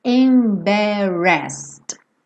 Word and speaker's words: embarrassed: Embarrassed. A mesma coisa, não vou pronunciar o E, então eu não falo embarrassed. Embarrassed.